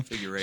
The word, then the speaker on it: Figueroa: Oh, and just the display of sportsmanship from, how do you say his name, Figueroa? – Figueroa.